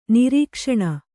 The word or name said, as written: ನಿರೀಕ್ಷಣೆ